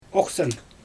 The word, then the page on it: oxsan: Xyzyl